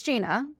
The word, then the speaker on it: Ginna: Well, and years ago when we first started running, like, okay, so it's Ginna, but it's obviously spelled with two N's, which confuses people.